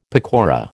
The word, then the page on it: pakora: American English